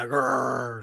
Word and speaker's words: Gerar: Today, we will be introduced to Abraham's new neighbor, the king of Gerar. Did I say that right? – Yeah, Gerar.